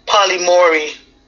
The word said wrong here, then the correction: polyamory: Polymory? I have a feeling you're not saying it right. I don't know how to Polyamory. – Say it. – Polyamory. It's P-O-L-Y-A-M-O-R-Y. – You can't even pronounce the letters right. – P-O-L-Y-A-M-O-R-Y.